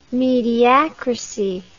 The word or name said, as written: mediacracy